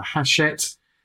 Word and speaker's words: Hachette: It's published by uh, FaithWords, which is a, a subsidiary or division of uh, Hachette uh, Book Group. Have I pronounced that correctly? Correct.